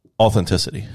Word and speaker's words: authenticity: It adds authentication. Did I say that right? I tried really hard to say it right. – I think it's authenticity.